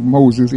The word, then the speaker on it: Mose: Fort Mose, and it's spelled like Moses, M-O-S-E, but it's pronounced Mose, Fort Mose of Florida.